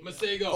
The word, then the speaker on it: Masego: It's either Masego or Masego. – Masego.